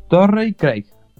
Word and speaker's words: Torrey Craig: Y un jugador ex Milwaukee que ahora se fue a Phoenix, al potente Phoenix, que está jugando cada vez mejor, Torrey Craig. ¿Lo dije bien? – Sí, Torrey Craig.